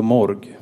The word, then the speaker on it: Morg: Budens namn var Morg. M och O flöt fint över pappret. R-et, det var supercoolt. G-et var lite besvärligt. Men namnet skulle uttalas som det engelska morgue, bårhus.